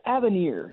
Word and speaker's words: Avenir: All right, we're talking, and she's now going to talk to us about the Buick Envision Avane. Or event, how do you say that? – I think it's Avenir. It's A-V-E-N-I-R. Okay. So I'm going to go with Avenir.